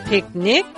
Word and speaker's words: picnic: Piknik dalam bahasa Inggris ditulisnya pakai P-I-C-N-I-C. Dibacanya piknik.